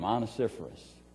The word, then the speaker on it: Onesiphorus: His name was Onesiphorus. Say that fast 10 times in a row. Onesiphorus, Onesiphorus. And that's how you pronounce his name, Onesiphorus.